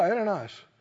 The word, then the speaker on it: Ananias: But in Acts 22 and 13, Acts 22, 13, you know, when was it Ananias that came and prayed for him. Is that right? Verse 12? Did I say that right? He said, Brother Saul? Yeah, Ananias.